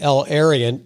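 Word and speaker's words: El-Erian: The El Arian. Did I pronounce that correct? – That's right.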